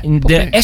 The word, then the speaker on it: Les Papés: Nee, de papé. S spreek je niet uit op dat moment. Okay. Les Papés. Okay. Les Papés moet je eigenlijk zeggen. Want de S, die spreken... De Fransen spreken de S namelijk zelden of nooit uit.